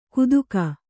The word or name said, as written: ಕುತುಕ